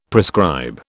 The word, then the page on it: prescribe: British English